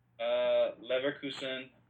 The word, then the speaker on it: Leverkusen: Leverkusen. That one's I probably butchered. – That was perfect.